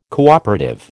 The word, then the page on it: cooperative: American English